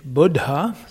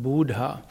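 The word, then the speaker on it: Budha: Merkur entspricht auch dem griechischen Hermes und auf Sanskrit ist es Buddha, nicht Buddha, sondern Buddha. Und Buddha mit einem D, das ist der Erleuchter. Buddha mit zwei D ist der Erleuchtete.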